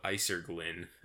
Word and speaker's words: acerglyn: It's called Isergin, apparently. – Really? – But it's spelled like A-C-E-R-G-L-Y-N.